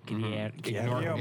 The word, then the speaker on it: Guillermo: Guillermo. Ignore the L's. – Guillermo.